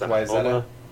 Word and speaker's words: Wayzata: And something called Wayzata, which is spelled Wayzata, but pronounced Wayzata.